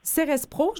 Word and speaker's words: Cérès Pro: Vous êtes enseignante de formation, auteure et fondatrice de Cérès Pro, je le prononce comme il faut, Julie? – Oui, exactement.